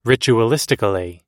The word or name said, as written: ritualistically